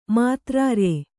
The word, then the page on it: ಮಾತ್ರಾರ್ಯೆ: Kannada